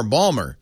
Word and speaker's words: Baltimore: Hey, this is a state where they pronounce Baltimore Balmer.